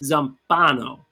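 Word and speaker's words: Zampanò: It's pronounced Zampano.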